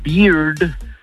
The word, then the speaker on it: bear: Porque con, o sea, la barba se pronuncia beard, el oso se pronuncia bear, ¿eh? Y la cerveza se pronuncia beer. Pero con R al final.